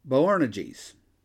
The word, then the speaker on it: Boanerges: To them he gave the name, I had to look this one up for pronunciation, And it's pretty much what it looks like. Boanerges, which means sons of thunder.